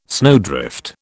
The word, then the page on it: snowdrift: British English